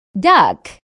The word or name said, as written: duck